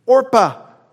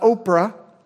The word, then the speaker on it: Orpah: Orpah, not Oprah.